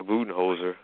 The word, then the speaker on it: Budenholzer: And you know, and you got to go with Mike Budenholzer.